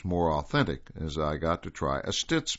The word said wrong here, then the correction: sitz: bath, sitz bath, I should say, that's spelled S-I-T-Z, vapor cabinet, and needle shower.